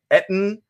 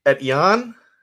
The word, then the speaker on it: Etienne: It is not supposed to be pronounced Etten. It is a French name, Etienne, and it is pronounced Etienne.